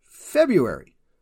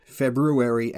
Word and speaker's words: February: But we say February. Foxtrot, Echo, Bravo, Uniform, Alpha, Romeo, Yankee. Why is this? Well, technically both pronunciations are correct. February and February. It's more a matter of whereabouts in the Anglosphere you hail from, but February is more common.